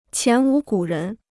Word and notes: 前无古人: qián wú gǔ rén

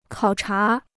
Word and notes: 考察: kǎo chá

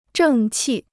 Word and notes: 正气: zhèng qì

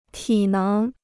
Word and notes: 体能: tǐ néng

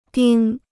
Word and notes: 丁: dīng

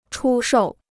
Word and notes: 出售: chū shòu

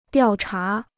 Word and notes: 调查: diào chá

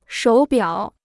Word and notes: 手表: shǒu biǎo

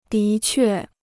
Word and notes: 的确: dí què

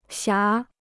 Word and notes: 霞: xiá

